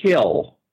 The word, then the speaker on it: kiln: But there's another pronunciation, and that's the one that our narrator Fred uses, and it's kill. – Kill, okay. – KILL sound. And I was like, he screwed this up through the whole thing, and then I went and looked it up. And I said, I better check this. And kill can be pronounced kiln or kill.